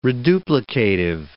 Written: reduplicative

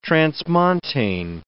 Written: transmontane

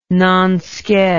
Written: nonsked

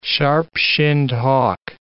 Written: sharp-shinned hawk

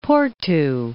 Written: Porto

